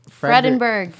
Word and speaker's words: Fredenburg: Fredenberg.